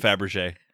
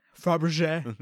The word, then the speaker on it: Fabergé: Fabergé. – Fabergé.